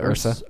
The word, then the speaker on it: Ursa: Yeah, the fight scene between Jaden Smith and did I say that right? – The Ursa. – Ursa.